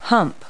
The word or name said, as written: hump